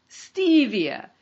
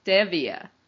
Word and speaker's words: stevia: Most of us say stevia with a long E, but Jim told me it's pronounced stevia with a short E.